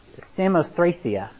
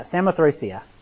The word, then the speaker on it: Samothracia: Therefore, loosing from Troas, we came into the straight course to I figured out how to pronounce this week because I made a fool of myself last week. And now, Samothracia. Yeah, Samothracia. And the next day to Neapolis, and from thence to Philippi, which is the chief city in that part of Macedonia.